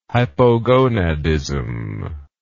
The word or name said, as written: hypogonadism